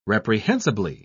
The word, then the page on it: reprehensibly: British English